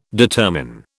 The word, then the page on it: determine: British English